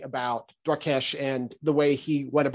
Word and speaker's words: Dwarkesh: And today we're going to talk about Dwarkesh Patel. I do not think I'm pronouncing his name right, by the way, but Dwarkesh, I think maybe is the right way to pronounce it, Patel, and his theory around the scaling hypothesis.